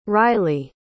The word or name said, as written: reilly